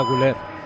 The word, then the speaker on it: Güler: A los turcos, cuando les he oído retransmitir, decían Arda Güler. – Ah, vale. – Por eso, Paco, yo. – La diéresis esa que tiene en la U, no sé si allí en Turquía no la pronuncian tal cual.